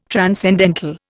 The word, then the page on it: transcendental: Indian